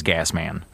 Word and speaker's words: Gassman: Okay, you said it wrong. It and I know you were trying to be nice. You didn't have to be nice. It actually is Gassman.